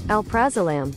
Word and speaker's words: alprazolam: Pronounce alfrazolam. – Alprazolam. – Alprazolam. Okay, I got that totally wrong.